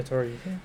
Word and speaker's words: Tari Eason: Tari Eason? – Yeah, Tari Eason.